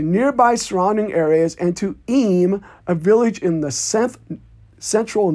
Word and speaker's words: Eyam: The last story we have is the supposedly true and inspiring story of the English plague village of Eyme, spelled E-Y-A-M, but I believe it's pronounced Eyme.